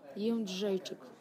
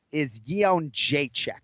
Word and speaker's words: Jędrzejczyk: Joanna Jędrzejczyk.